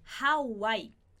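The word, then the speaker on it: Hawái: Por exemplo, a palavra Havaí, em espanhol, a gente escreve dessa forma e a gente vai pronunciar Hawaii. Hawaii. Vou colocar aqui a forma como você vai pronunciar essa palavra. Hawaii, ok? Com esse H que eu coloquei, aquele sonzinho que sai pela garganta. Hawaii.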